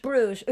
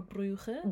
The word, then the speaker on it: Bruges: How do you say that? – Bruges. – Oops. – Or Bruges. – Bruges. Bruges. – Depending on French or Dutch. You have Brussels, Bruges or Brugge.